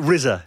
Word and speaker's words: RZA: How do you say, is it RZA? Is that how you pronounce his name? – Yeah, okay. That's the one.